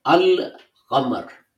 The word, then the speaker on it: Al-Qamar: If you stick a Hamza, now if you stick an Al in front of Qamar, you won't drop the Lam, but you will pronounce Al in the full. You will say Al-Qamar.